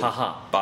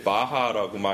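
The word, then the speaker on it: Baja: 바하라고 말하면 뭐 생각나는지 알아? 미국의 바하 멕시코이라는 지역 있어요 캘리포니아 아래 있는 반도 거기는 바하 근데 그것도 스페인어이니까 B-A-J-A인데 스페인어로 J는 H처럼 발음하거든요 아무튼 네 우리 충분히 많은 잡담했죠?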